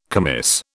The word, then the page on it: commis: British English